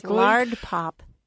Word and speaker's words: Lardpop: I hope I'm pronouncing that correctly. Yes, Lardpop.